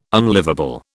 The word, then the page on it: unlivable: British English